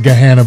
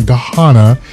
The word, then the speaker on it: Gahanna: I know it's pronounced Gahanna, but I like saying Gahanna, 134 North Hamilton.